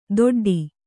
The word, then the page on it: ದೊಡ್ಡಿ: doḍḍi